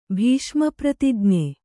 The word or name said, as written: ಭೀಷ್ಮಪ್ರತಿಜ್ಞೆ